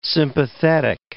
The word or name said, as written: sympathetic